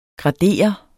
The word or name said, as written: gradere